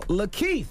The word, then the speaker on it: LaKeith: Goes by the name of Lakeith.